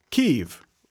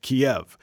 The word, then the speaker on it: Kyiv: Ukrainians call it Kyiv. Russians call it Kiev. We probably should go with the Ukrainians, no?